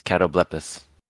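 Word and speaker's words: Catoblepas: It just is Catoblepis. – Catoblepis.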